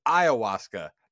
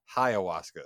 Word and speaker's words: ayahuasca: It is ayahuasca, not hiawaska.